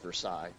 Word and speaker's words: Versailles: This was still in Missouri, in Versailles, actually, which is spelled Versailles, but pronounced Versailles.